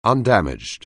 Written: undamaged

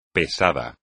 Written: pesada

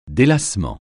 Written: délassement

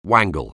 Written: wangle

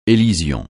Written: élision

